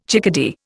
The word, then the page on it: chickadee: Indian